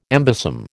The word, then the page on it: embosom: American English